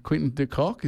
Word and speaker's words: Quinton de Kock: Yeah, Quinton de Kock.